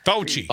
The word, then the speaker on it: Fauci: I think what the Republicans did, the Fucci, how do you say that name? – Fauci.